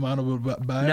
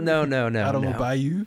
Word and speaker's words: Adebayo: How do you say it? – Bam Adebayo. – Bam Adebayo. Mm-hmm. All right. Not Bam Adebayo. – No. – Adebayo. – Adebayo.